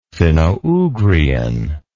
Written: finno ugrian

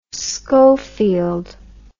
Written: scofield

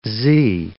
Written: zee

